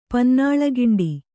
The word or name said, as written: ಪನ್ನಾಳಗಿಂಡಿ